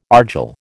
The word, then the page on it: argil: American English